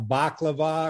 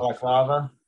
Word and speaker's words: balaclava: And it is one of those, I think they call it, like, I'm going to pronounce this wrong, a baklava or a balavaka, yeah, or whatever they're called. – Balaclava.